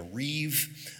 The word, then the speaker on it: rib: Uh, the, the technical term there is it's called a reeve, uh, R I B, but you pronounce that B as a V there.